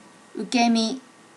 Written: ukemi

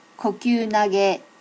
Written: kokyunage

